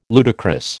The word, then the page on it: ludicrous: American English